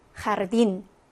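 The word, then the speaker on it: jardín: 영어의 J니까 지읒 발음 난다라고 생각을 하시지만 아닙니다. 강한 히읗 발음입니다. 자, 그러면 어떻게 읽으면 될까요? 이 단어? 맞아요. 하르딘 하고 읽으시면 되고, 이 단어는 호벤 하고 읽어주시면 됩니다.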